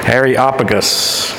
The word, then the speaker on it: Areopagus: Areopagus. Okay? Areopagus.